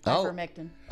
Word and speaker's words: ivermectin: What the hell is ivermectin. – Going on? – Pardon me? – Ivermectin.